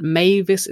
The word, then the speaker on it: Mavis: It's Victoria with a C, and Mavis is spelled M-A-V-I-S.